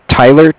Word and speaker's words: Tyler: My name is Tyler, T-Y-L-E-R. – Yep. – And I'm calling with Cardiff. It's spelled C-A-R-D-I-F-F, as in Frank Frank.